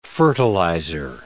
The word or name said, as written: fertilizer